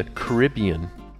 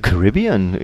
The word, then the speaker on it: Caribbean: How would you say the Caribbean in the UK? – The Caribbean, we pronounce that the Caribbean.